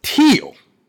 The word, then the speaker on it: Thiel: She goes by Beth, big fan of West Virginia University, and Thiel, pronounced Teal College.